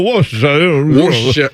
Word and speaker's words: Worcestershire: And of course, over there, they just chew on marbles when they talk to like, oh, it's a Worcestershire. And now we try to say it like we're looking at the letters that are in it. And we're like, well, that's how that's pronounced. And it's clearly not.